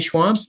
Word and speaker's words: Schwamb: Kenny Schwamb? – Yep, absolutely.